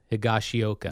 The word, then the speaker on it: Higashioka: Kyle Higashioka.